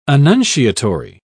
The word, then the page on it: annunciatory: British English